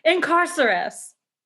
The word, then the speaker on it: Incarcerus: I am sorry, I mispronounced that. Incarcerus.